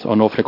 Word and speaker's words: Onofre: For the record, my name is Onofre Contreras, and for the record, that's spelled O-N-O-F-R-E, for those taking notes.